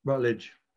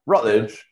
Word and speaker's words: Routledge: Did you say Routledge or Routledge? – Yeah. Rutledge. – Rutledge in February. – Just swing just over the first syllable.